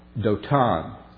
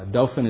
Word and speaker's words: Dothan: Dothan is the good southern pronunciation. I remember hearing a guy from up north being in Alabama one time and said he was going to go down to Florida through Dothan. He simply did not get the right pronunciation.